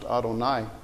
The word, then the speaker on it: Adonai: There's another word, Lord, which is capital L with lowercase O-R-D, which is pronounced Adonai.